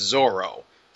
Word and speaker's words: Zoro: Yeah, well, I believe it's always been written as Zolo, but it's always been pronounced as Zoro.